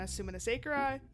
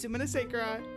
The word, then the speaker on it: Sumanasekera: And this week we are so excited to bring you our interview with Tiana Sumanasekera. Yes, that's how you pronounce it.